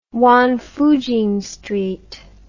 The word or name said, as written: Wangfujing Street